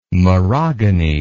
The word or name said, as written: merogony